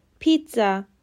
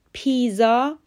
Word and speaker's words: pizza: D-E-S-E-R-T به معنای بیابان دزرت خونده میشه و استرس روی سیلاب اوله. لغت پنجم P I Z A پیتزا دقیقا همون تلفظی که در فارسی میگیم پیتزا رو باید بگیم پیزا غلطه